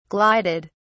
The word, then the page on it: glided: British English